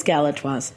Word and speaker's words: Galatoire's: Am I saying it right? – It is Galatoire's.